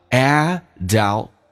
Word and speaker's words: adulthood: 要记住这个单词重音是在 a 上有点难叫 adulthood 那个 t 可以不用发音